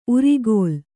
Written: ಊರೆಗೋಲು

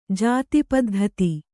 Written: ಜಾತಿಪದ್ಧತಿ

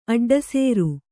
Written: ಅಡ್ಡಸರ

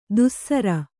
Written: ದುಸ್ಸರ

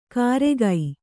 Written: ಕಾರೆಗೈ

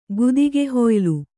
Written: ಗುದಿಗೆಹೊಯ್ಲು

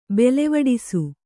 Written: ಬೆಲೆವಡಿಸು

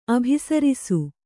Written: ಅಭಿಸರಿಸು

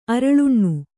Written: ಅರಳುಣ್ಣು

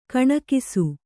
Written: ಕೆಣಕಿಸು

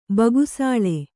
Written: ಬೆಗಸಿಲೆ

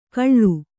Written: ಕಳ್ಳು

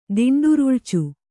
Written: ದಿಂಡುರುಳಿಚು

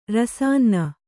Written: ರಸಾನ್ನ

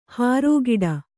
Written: ಹೊರಕಡೆ

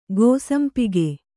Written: ಗೋಸಂಪಿಗೆ

